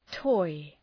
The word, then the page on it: toy: tɔı